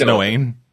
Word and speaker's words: Noein: It's either Noein or Noin. It's from 2005. And it seems to be a coming-of-age time travel apocalypse story. – Oh. Well, that's so nice. And I'm pretty sure it's Noein. – No, okay. In terms of the Japanese pronunciation, it would be Noein.